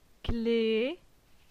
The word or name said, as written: Cle